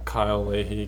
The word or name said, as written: Kyle Leahy